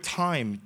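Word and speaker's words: time (τιμή): The word honor is the Greek word time, T-I-M-E.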